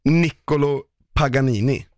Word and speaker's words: Niccolo Paganini: Han hette Nick Vänta lite, nu ska jag bara se om jag kan uttala det. Nicki Minaj. Niccolo Paganini. Oj då. – Det låter som en Panini.